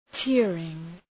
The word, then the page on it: tearing: ‘teərıŋ